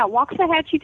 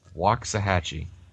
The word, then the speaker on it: Waxahachie: yeah, Waxahachie, Texas. – Waxahachie. – Yeah. – Okay, I think I got it, but please don't ask me to say it again. Waxahachie.